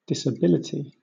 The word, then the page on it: disability: British English